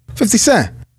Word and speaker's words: 50 Cent: 50 Cent.